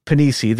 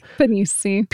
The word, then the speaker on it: Pennisi: Now, again, cue the Agent ETA joke because the last name is spelled Panisi. – P-E-N-N-I-S-I. So it's nearly the word penis, but not quite. You know, it's got an extra N and an extra I in there, unfortunately.